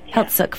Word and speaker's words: Heiltsuk: And excuse me if I say this wrong, is it the Heiltsuk First Nation? – Heiltsuk, yeah.